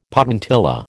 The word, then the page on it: potentilla: American English